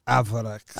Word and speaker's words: averechts: Avrex. It's Dutch. – Oh, it's a Dutch word.